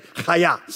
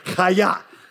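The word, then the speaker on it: Hayah (היה): Hayah, say it. If you didn't get a little bit on your neighbor, you didn't say it right, okay? Hayah.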